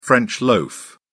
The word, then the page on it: french loaf: British English